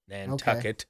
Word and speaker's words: Nantucket: Nantucket. Okay. It rhymes with bucket.